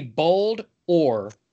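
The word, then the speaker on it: Boldore: Bulldor? – Would you say Boldor or Boldor? – I would say Boldor because he's like ore, like rock, iron ore, and he's bold. Boldor.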